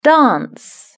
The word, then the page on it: dance: British English